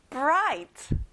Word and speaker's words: bright: 친구들, GH 발음은 안 나요. bright.